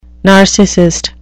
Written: narcissist

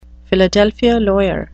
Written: Philadelphia lawyer

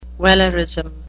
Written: wellerism

